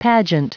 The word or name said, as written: pageant